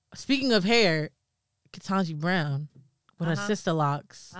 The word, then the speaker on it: Ketanji: Ketanji.